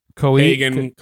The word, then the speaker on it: Keoghan: Well, I mean, I like Barry Co. How the fuck do you say his name? – Kogan. – I like Barry Keoghan's character.